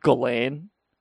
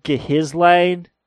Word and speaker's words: Ghislaine: It's Ghislaine. It's Ghislaine, you dumb fuck. It's not Ghislaine. It's pronounced Ghislaine.